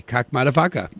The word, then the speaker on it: Kakkmaddafakka: You might otherwise miss new tunes there, or new tune there from Kakmadafaka. That is spelled K-A-K-K-M-A-D-D-A-F-A-K-K-A, Kakmadafaka.